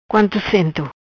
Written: Cento Quanto

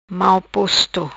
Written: Mal posto

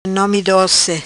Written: Nomi Doci

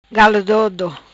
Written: Galo Dodo

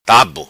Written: Tabu

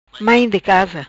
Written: Mai di casa

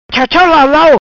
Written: Chau Chau Lau Lau

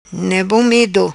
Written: Ne Bom Medo

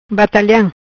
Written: Bataliam

